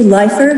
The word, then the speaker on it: Leifer: Members of the committee, my name is Nancy Leffler. That's spelled L-E-I-F-E-R.